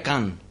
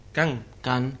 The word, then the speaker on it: Cannes: Por cierto, que en francés se diría Cannes. – Can, claro, porque la S no se pronuncia.